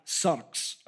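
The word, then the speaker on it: sarx (σάρξ): So the word flesh in the language of the New Testament, in the Greek, is pronounced like sarx, okay? S-A-R-X, if we were to transliterate it into our alphabet.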